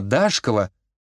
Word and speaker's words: Дашкова: И в кои-то веки Дарья Сергеевна Дашкова, ударение строго на первую «А», даже не подумала спорить.